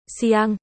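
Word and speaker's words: Siang (象): Vì Hướng. Siang. Và tượng. Siang. Đều đọc là. Sàng.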